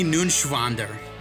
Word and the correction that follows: Neuenschwander: You didn't pronounce it. Anthony? – Anthony Nunschwander. – It's N-E-U-E-N. It's Neuenschwander. Anthony Neuenschwander.